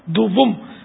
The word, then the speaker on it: دوم: اول خلیفہ سیدنا حضرت ابو بکر صدیق رضی اللہ تعالی عنہ, دوم خلیفہ جس کو عام عوام دوم پڑتی ہے, دوم دوم نہیں اصل تلفظ ہے, دوم دال اور واو پر پیش ہے, نہ بھی لگائیں پیش کوئی ضرورت نہیں.